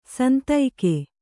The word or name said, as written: ಸಂದೇಗ